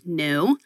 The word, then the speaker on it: NXU: And we just did a rebrand yesterday from Atlas Motor Vehicles, and now the company is NXU, pronounced new.